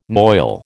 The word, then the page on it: noil: American English